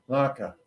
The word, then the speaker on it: latkes: The potato latkes, is that how you pronounce it? How do you say it? Laka.